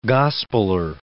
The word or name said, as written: gospeler